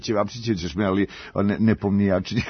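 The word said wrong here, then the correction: Nepomnišći (Непомнящий): kako to teže mi je. – Nepomnišći. – Nepomnišći, dobro.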